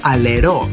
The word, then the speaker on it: Αλερό: Αλέρο Seaside στην Σκύρο. Στο Αλερό Seaside στη Σκύρο, για να το πω και εντελώς σωστά με προφορά. Αλερό, Αλερό Seaside Σκύρος.